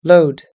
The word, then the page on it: load: American English